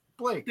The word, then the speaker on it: Blacke: It's Blake. – Yeah, but it's spelled Blacke, I thought. – It is. – Yeah. – Yeah. So, silent. Yeah, it's a very silent. – The C is silent, yeah.